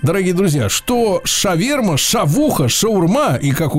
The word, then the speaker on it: шаурма: Если вы читаете это слово э, так же, как англоязычные люди, то должны говорить шаурма, Потому что пишется в оригинале шаварма. Ну, шаверма это наиболее близкое произнесение, да?